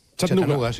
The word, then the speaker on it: Chattanooga: ¿Se pronuncia así en Chattanooga? – Chattanooga. Chattanooga City.